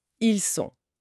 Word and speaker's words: sont: Ils sont. Le T à la fin est muet.